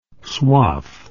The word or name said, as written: swath